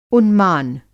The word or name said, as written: उन्मान